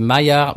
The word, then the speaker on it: Maillard: Alors je sais pas comment ça se prononce en français, mais c'est Mallard, euh, Maillard. Bah, on dirait que c'est, on dirait Maillard en français.